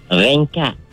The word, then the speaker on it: rengkat: Ringkat. – Ringkat. – Ringkat. Ejaan dia R-E-N-G-K-A-T. Atau dia ada satu lagi, dia tak sebut ring. Mm-mm. Dia sebut ring. Ringkat. Ejaan dia R-I-N-G-K-A-T.